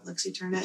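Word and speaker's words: Lux Æterna: Is it Lux Eterna? – Lux Eterna. – Glad they're back. – Is it A or an E? – It's like an A and an E.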